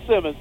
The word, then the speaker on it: Simons: Now, there's a lot of folks over here that spell it Simons and it's pronounced Simmons.